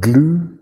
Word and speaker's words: Gluu: We came across this OAuth server called Glue. That's spelled G-L-U-U.